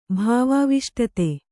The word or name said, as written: ಭಾವಾವಿಷ್ಟತೆ